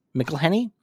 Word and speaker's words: McElhenney: Rob McElhenney, is that how you say it? – McElhenney. – Yeah, I believe so.